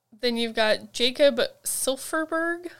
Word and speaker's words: Silfverberg: Jacob Silfverberg. I know it's like extra letters there, but yeah. – It's like French. You only pronounce half the letters.